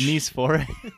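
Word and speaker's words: Nicéphore: Nicephore. – It's not pronounced nice, as in very nice. – Right. – The French way of saying it sounds like niece for.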